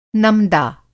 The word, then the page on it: नमदा: Hindi